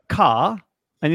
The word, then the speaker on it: Cara: It's quite easy. It's like a car and it's just Cara. – Yeah, right? – it's two simple syllables.